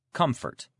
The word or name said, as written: comfort